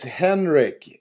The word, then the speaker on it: Henrik: It's Henrik. It's the German version of Henry. – All right. – Or the French is Henri.